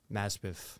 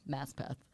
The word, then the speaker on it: Maspeth: Maspeth. – Maspeth. So the signs I read out the, like, window on the train are correct. It's pronounced how it's spelled, Maspeth.